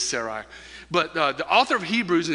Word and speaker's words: Sarai: It's Sarai. Sarai, yes. Some people were asking me before service, how do you pronounce her name? I pronounce it Sarai with an I on the end.